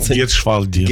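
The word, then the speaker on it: Gietrzwałd: Поміж тим, ви з паломницькими групами буваєте часто в Люрді, знову ж таки в Фатімі, в Ля-Салет, в Гетшвальді, я правильно вимовив? – Гетшвальді. – Гетшвальді.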